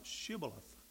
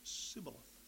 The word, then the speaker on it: Shibboleth: He said, if he said no, then they would say to him, "Say now, Shibboleth." But he said, "Sibboleth," for he could not pronounce it correctly.